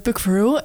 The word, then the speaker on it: Puck Verheul: Verhul, zeg ik dat goed zo? Ja, het is Puck eh, Puk Verhul.